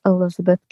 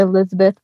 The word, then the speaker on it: Elisabeth: My at sign is Coach Elisabeth King, and emphasize on the Elisabeth part because my name is spelled differently than most Elizabeths.